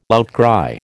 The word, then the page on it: outcry: American English